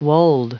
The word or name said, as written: wold